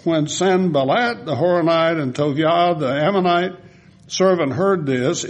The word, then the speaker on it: Tobiah: But when Sanballat the Horonite and Tobiah, by the way, this is T-O-B-I-A-H, that I-A-H is J-A-H, Yah. And so be sure that you don't say it like Isaiah, Isaiah. You say Isaiah, Jeremiah. Okay, that's the short form of the name of God.